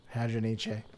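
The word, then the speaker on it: Hadronyche: N-Y-C-H-E. – Niche? – I don't think... – Hadronic? – That sounds too French. – Hadronice? – Yeah, I think you need to pronounce the E. – Hadronice.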